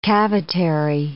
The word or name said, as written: cavitary